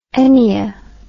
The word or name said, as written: ennea